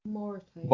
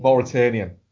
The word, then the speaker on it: Mauritanian: I can't, I can't, I'm trying to remember how you say it. Uh, for the Mauritanian. – Mauritanian. – Mauritanian, right? Where literally, and I haven't seen this happen in a trailer in ages.